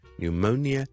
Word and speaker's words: pneumonia: 所以肺炎我哋係 pneumonia， pneumonia， pneumonia。 Even though 佢開頭係有個 P P N E U M O N I A，仲有啲咩 silent P 嘅字咧？Psychology P S Y S H O L O G Y 心理學，雖然有個 P 喺度，但係我哋都係唔讀嘅，所以咧就咁你當係 S 開頭出啦。